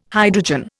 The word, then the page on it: hydrogen: Indian